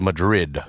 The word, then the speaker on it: New Madrid: It's New Madrid, right? – Madrid, I believe. – New Madrid? – It's the New Madrid fault. – We call it Madrid.